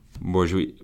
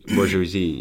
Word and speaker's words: bourgeoisie: But then later on, they go on to say the modern bourgeois, or how did you pronounce it earlier? – Bourgeoisie. – Bourgeoisie, yeah. I don't know.